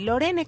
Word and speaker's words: Lorene: Se escribe O-U-I-C-H-E o u i c y es Uiche, no, Ouiche y Lorene, como Lorena, pero Lorene.